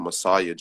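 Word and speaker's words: Massiah: They could find me on all social medias and all music streaming platforms with my Instagram name, Messiah G. Messiah spelled with the M-A-S-S. Usually people like spell it wrong and they type it as M-E-S-S-I-A-H, but it's with an A, so...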